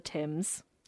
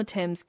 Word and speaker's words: Thames: It says, as the Adventure Galley sailed down the Thames.